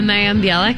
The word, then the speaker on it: Mayim Bialik: Mayim Bialik. – Mayim Bialik, yeah, filling in for on the Jeopardy thing here.